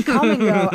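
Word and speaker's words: Kum & Go: And the come part is spelled K-U-M. So it looks like they know what they're saying. So it's Come and Go.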